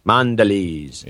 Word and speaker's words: Mondelez: Mondelez.